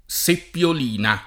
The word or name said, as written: seppiolina